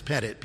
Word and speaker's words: Pettit: Like my last name's Pettit. People call me Petite. I said, I'm not small.